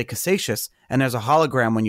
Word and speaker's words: Casascius: His name is Mike Caldwell, and he created the first physical Bitcoin, correctly pronounced as Casascius.